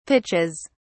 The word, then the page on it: pitches: British English